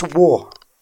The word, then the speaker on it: Warragul: So it's Warragul. Warragul. – Warragul.